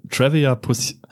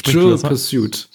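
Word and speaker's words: Trivial Pursuit: Oder wenn Hardy mal wieder sein Trevia, Trevia Pursuit, ich kann es nicht aussprechen. Trevia Pursuit? Trivia Pursuit. – Trivi Pursuit. – Pursuit. – Trivial glaube, Pursuit.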